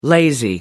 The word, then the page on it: lazy: American English